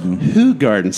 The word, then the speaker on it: Hoegaarden: One of the most noteworthy and a very sizable glass that has a wit beer in it is Hoegaarden. – Hoegaarden. – See that? Thanks. That is the true way to pronunciate that.